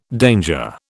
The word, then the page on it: danger: British English